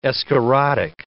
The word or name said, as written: escharotic